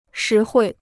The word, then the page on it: 实惠: shí huì